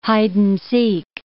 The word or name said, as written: hide-and-seek